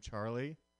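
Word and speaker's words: Charley: Middle name Charlie. So Honest that Charlie is spelled L-E-Y, not L-I-E.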